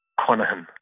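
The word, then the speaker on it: Conaghan: But the actual pronunciation is Conahan.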